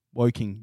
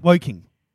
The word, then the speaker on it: Woking: You did pronounce half of the word. – I did? – Yeah. Woking. You were like, oh. – Fuck, it is Woking. – Woking.